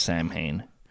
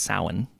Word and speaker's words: Samhain: It was called a Samhain, spelled Samhain. But it's not pronounced Samhain. It's pronounced Samhain.